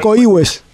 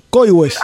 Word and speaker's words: Coihues: Se dice Coihues. – Coihues. Ah, con acento en la I. No, con acento en la O. Coihues.